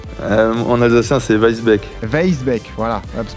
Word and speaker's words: Weissbeck: Gaëtan, je dis bien Weisbeck, ça va la prononciation Ouais, y a pas de souci.